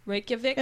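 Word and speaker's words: Reykjavík: Sorry if I butcher this word, but it's Reykjavik. – Yeah. – Reykjavik? – Yeah, it's pretty good.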